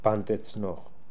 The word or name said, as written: Pandetznokh